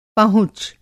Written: पहुँच

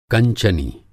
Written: कंचनी

